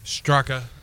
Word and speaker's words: Straka: It'll be Sepp Straka Straka? How do you say his name? – Straka. He's actually hit a hole-in-one during one of his practice rounds this week already.